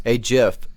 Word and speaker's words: GIF: Is it just me, or can you hear this GIF? – And I do like that pronunciation with the hard G there, just to get that one out there on the topic of language.